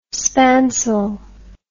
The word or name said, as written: spansule